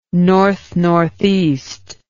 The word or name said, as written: north northeast